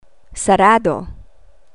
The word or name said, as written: sarado